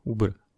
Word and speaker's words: Uber: Trochu možná překvapivě, nikdo to nečekal, ale oni prodali jako svůj mapový biznis, Uber, nebo jak se ta služba jmenuje, nebo čte se to? Uber.